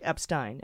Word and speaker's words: Epstein: So if it's E-I, it's pronounced I, like Epstein.